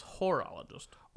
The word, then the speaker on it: horologist: So basically, if you want an orologist or a dog walker. – Yes. – I keep on saying orologist, but it's horologist.